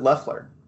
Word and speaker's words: Loeffler: They actually pronounce it Loeffler. That's how it's pronounced.